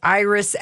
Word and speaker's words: Iris: Well, this Idris. – Okay, Idris. – Say her name. – Her name is Iris Apfel. – Thank you. Iris. She's a wrinkle-free 96-year-old Barbie because it's hard to do wrinkles in plastic.